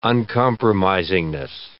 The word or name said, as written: uncompromisingness